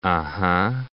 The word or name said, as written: uh-huh